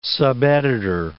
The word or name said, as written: subeditor